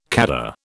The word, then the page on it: khaddar: British English